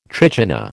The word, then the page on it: trichina: American English